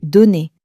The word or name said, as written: donner